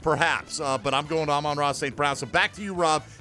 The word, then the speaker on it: Amon-Ra St. Brown: Here's how you say it for folks who need to know. Amon-Ra St. Brown.